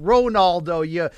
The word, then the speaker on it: Ronaldo: his name is 100% pronounced Ronaldo.